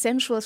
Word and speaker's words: Sensual: Sensual, spricht man Englisch aus? – Ja, genau.